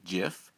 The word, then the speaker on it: GIF: Okay, first I would just like to ever so gently remind you that the correct pronunciation is actually GIF. That's with a soft G. Shout out to the soft G.